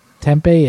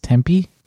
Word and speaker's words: Tempe: Apparently, we've been saying Tempe, it's Tempe.